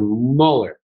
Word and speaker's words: Muller: You know, I really love the way that you pronounce my last name. It just makes it sound even more exciting. Mr. Muller.